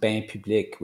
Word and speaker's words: Bain Public: And actually, it's pronounced Bain Public, which is a French word for public bath.